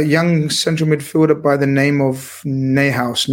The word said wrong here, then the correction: Neuhaus: Newhouse. I don't know if Avi wants to correct my pronunciation. You're the man for pronunciations, as long as they're not Portuguese. Florian Neuhaus. Florian Neuhaus.